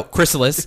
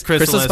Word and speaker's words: Chrysalis: Crystals by Empire of the Sun. – No, Chrysalis. Chrysalis by the Empire of the Sun.